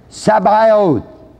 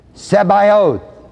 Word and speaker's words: Sabaoth: The ninth usage of the word Jehovah in the Bible is the word Jehovah Sabaoth, Z-E-B, long A, long O-T-H, Jehovah Sabaoth.